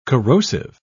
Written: corrosive

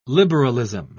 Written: liberalism

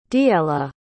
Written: diella

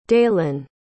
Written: daylan

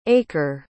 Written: aeker

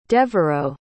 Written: deveraux

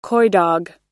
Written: coydog

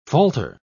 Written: falter

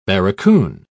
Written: barracoon

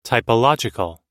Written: typological

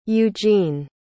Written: eugene

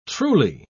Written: truly

